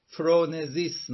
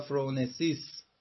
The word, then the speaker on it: فرونسیس: در فلسفه ارسطو مقوم حکمت عملی فرونزیس نامیده میشه. فرونزیس یا فرونسیس، حالا تو تلفظ ز ترجمه میکنیم ولی ز نیست، سیه، سیگماستی. فرونسیس چی هست؟